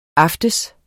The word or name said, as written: aften